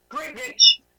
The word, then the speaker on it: Greenwich: It's not for all its like spelling, you know, like from the pronunciation of the word to the spelling of the word was like. – That's English for you. – Greenwich. – Yes, it's like Greenwich, and it's like Yorkshire.